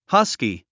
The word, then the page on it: husky: hʌ́ski; ハスキー